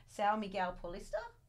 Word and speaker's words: São Miguel Paulista: São Miguel Paulista? – Yep, that's right.